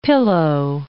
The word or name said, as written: pillow